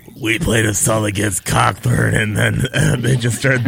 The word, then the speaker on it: Cockburn: Is it Colburn or Cockburn? – Cockburn. – Yeah. – I think.